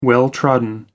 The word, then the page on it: well trodden: American English